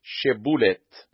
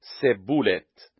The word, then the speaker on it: شبولت: و اگر می گفت نی، پس او را می گفتند بگو شبولت و او می گفت سبولت، چون که نمی توانست به درستی تلفظ نماید.